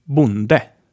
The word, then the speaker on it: bonde: And then we have a short O, like in bonde, which means farmer. Bonde.